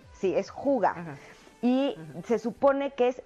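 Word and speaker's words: hygge: Es el arte de ser feliz en lo sencillo y se llama juga. Se escribe H Y G G eh, H Y Ay, ya se me olvidó.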